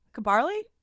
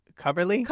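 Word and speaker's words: Cubbarli: It's C-U-B-B-A-R-L-I. Kabarly? – Cubberly? – Cubberly? Cubberly! – Cubberly? – Cubberly. – Cubberly, one of them. – The emphasis is on the wrong syllable.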